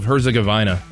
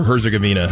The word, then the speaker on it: Herzegovina: By the way, compliments on the pronunciation of Herzegovina, or Herzegovina, however you want to pronounce that. Nicely done. That's a difficult one for people to do sometimes.